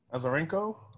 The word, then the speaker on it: Azarenko: Azarenko.